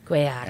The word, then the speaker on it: Cuellar: Am I saying that right? Cuellar? – Well, you could say Cuellar, or you could go heavy on the pronunciation and be like Cuellar. – Cuellar. – And you could do that. But you don't necessarily. Right. Whatever. I always say John Cuellar.